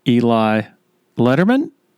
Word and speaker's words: Eli Lederman: Okay, joined by Eli Lederman.